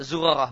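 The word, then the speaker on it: Zurarah (زرارة): Ya, Zurarah bacanya, sebagaimana kata An-Nawawi. Zurarah dengan za-nya di dhamma.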